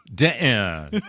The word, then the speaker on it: Denton: Sandra Denton. Do you know that name? Does that name ring a bell to you at all? – Is it Sandra Denton or Denton? – Denton. It's yes. D-E-N-T-O-N.